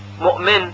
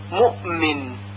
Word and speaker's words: مؤمن: مثلا کلمه مؤمن، مؤمن، مؤمن، که اکثر قراء هم به همین صورت که تلفظ شد تلفظ میکنن، مؤمن، مؤمن، یعنی مثل کلمه یأتی، یأتی تلفظ نمیشه که فقط شده و جهریت همزه باشه، بلکه به خاطر تلفظ حرف بعدی که میم هست همزه یک حالتی تقریبا شبیه به قلقله پیدا میکنه.